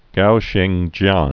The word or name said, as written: Gao Xingjian